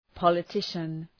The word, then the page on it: politician: ,pɒlı’tıʃən